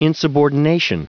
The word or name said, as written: insubordination